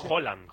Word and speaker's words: Haaland: Y cuando vos te topás con uno más o menos fácil, la pronunciación correcta es totalmente distinta. – Como Haaland. – Flaco. – Por ejemplo. – Claro, Haaland... – Es Haaland. – Haaland. Como si dijeras un queso, ¿no? – ¿Sabes qué pasa el martes?